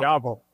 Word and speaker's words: Diavel: We should not admit what speeds we were going, but we crossed the Rocky Mountains at ungodly speeds in the Ducati Diavel. Right? – Diavel.